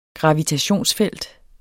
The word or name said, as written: gravitationsfelt